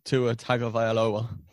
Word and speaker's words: Tua Tagovailoa: Next up, the Miami Dolphins, and they select shock Tua Tagovailoa. Probably that said that wrong, but anyway. – No, that was good.